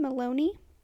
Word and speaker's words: Maloney: The first story we're going to read is called The Dead City, and it's by the user Darren Maloney. And rhymes with baloney.